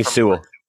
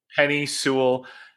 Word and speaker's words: Penei Sewell: It's the offensive Penei, Penei Sewell. – Penei Sewell.